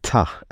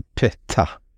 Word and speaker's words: Ptah: I skapelsemytologin från Egypten så kom ju guden, nu ska jag kunna uttala detta, men Ta eller Peta. Det stavas P-T-A-H.